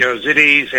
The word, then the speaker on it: Yazidis: Yazidis, and you've got Orthodox, and there's multiple religions, Not just religions.